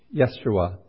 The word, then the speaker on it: Yeshua: Then the angel of the Lord showed me Yeshua, which is the Hebrew pronunciation for Joshua, the high priest standing before the angel of the Lord.